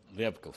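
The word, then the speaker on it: Ryabkov (Рябков): Elliot Abraham se entrevista en Roma con Sergei Ryabkov. ¿Así se pronuncia? – Ryabkov, sí.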